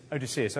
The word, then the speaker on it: Odysseus: Odysseus.